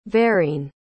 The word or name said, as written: verene